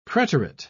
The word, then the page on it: preterit: British English